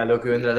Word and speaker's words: Lo que vendrá: Like, for example, I love the Lo que vendrá. Am I pronouncing correctly? – Yeah, Lo que vendrá.